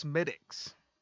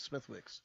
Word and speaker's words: Smithwick's: It's pronounced Smittix. – Oh, is it? – Yeah. – Thought it was Smithwick's. – Yeah, but Smithwick's Red Ale, Irish made. – So it's Smittix.